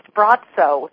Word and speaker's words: Braco: And just to let you know and your listeners know, his name in Croatian is pronounced Braco. – Oh, even though in English we would look at it and say Braco.